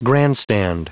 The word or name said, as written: grandstand